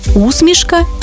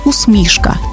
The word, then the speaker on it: усмішка: До речі, тут подвійний наголос – усмішка і усмішка, як у Василя Симоненка.